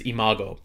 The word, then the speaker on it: Imago: I believe how you say it is Imago. For everybody that doesn't know, Imago, which sounds a lot like Iago, speaking of Disney, sounds like the parrot from Aladdin.